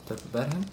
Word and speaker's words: Tubbergen: I'm thinking of some, In Tubbergen, in the Netherlands, I don't know why I say Tubbergen, it's Tubbergen, in Tubbergen. – You're saying a Dutch name with an English pronunciation.